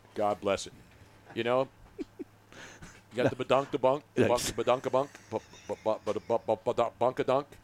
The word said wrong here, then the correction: badonkadonk: How do you say it, Robin? – Badonkadonk. – Badonkadonk. Sorry.